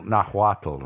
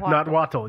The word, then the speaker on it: Nahuatl: Nahuati. – How is that again? – Nahuati. – Nahuatl, I'm sorry. – Nahuatl, yes.